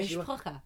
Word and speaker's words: mishpacha: I'm actually saying that right. Yeah, mishpacha.